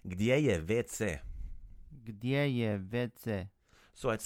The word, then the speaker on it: WC: So vetse is basically the letters WC pronounced the Croatian way, and WC is water closet.